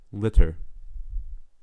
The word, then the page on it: litter: American English